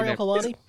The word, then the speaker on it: Ariel Helwani: Ariel Helwani. Sorry, Mr. Helwani. – Oh, you're right. I've got Ariel Helwani.